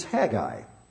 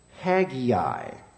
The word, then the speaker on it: Haggai: Well, the preferred pronunciation of this book is Haggai. In my circles, in Bible college, the common pronunciation was Haggai. And so both are correct.